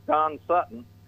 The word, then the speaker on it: Sutton: It was Don Sutton. – Well, you should have said rhymes with mutton.